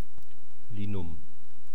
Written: linum

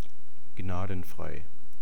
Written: gnadenfrei

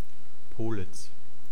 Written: pohlitz

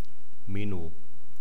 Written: menow

